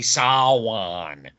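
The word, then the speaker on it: Samhain: I don't know, but it's spelled Sam Hain, but it's actually Sawan.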